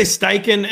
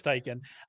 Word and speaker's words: Steichen: I think that Steichen is, am I saying that right? I actually have never figured out how to say his name. I say Steichen.